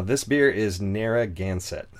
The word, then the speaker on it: Narragansett: Ah, you nailed it first try. – I had to practice a lot. – I literally looked it up on YouTube on how to pronounce it. – Oh, and if you hear like an East Coaster pronounce it, like you're adding extra stuff. Narragansett. – Narragansett. – Like I think they're taking away stuff, but adding stuff elsewhere.